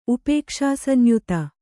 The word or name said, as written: ಉಪೇಕ್ಷಾಸಂಯುತ